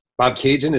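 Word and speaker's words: Bobcaygeon: I did pronounce that correctly, right? – Bob Cajun. That's perfect. – Bob Cajun. I just want to say the spelling of Bob Cajun is B-O-B-C-A-Y-G-E-O-N, a town in Ontario.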